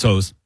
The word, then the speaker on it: Oz: It's Oz. Yeah, Oz. It's spelled Oz, but it's pronounced Oz.